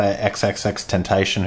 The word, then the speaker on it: XXXTentacion: But I thought it was interesting because him and that other rapper, XXXTentacion, who got killed... – is that how you say it? – Yeah, I think so, earlier in the year.